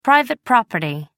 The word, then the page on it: private property: American English